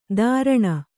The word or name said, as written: ದಾರಣ